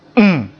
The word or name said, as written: m